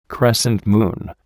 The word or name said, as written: crescent moon